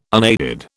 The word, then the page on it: unaided: British English